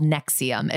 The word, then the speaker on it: NXIVM: So this episode is based on a cult for sure that you've probably heard of called Nexium. It's spelled like with Roman numerals, N-X-I-V-M, but it's pronounced Nexium,